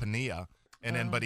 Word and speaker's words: Piniella: It's Lou Piniella. – Oh, it is? – But everybody, yeah. – Oh, there's no, the L is silent. – No, the L is a Y. Double L in Spanish is Y.